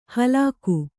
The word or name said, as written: ಹಲ್ಕ